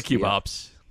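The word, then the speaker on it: QB ops: How do we how do we pronounce? – We say we say cube ops. – Cube ops.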